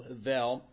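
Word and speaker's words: hebel: So it's H-E-B-E-L, but the B is pronounced as a V. All right? So Havel. And so in that moment,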